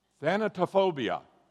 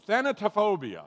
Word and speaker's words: thanatophobia: Thanatophobia. Say it with me. Thanatophobia.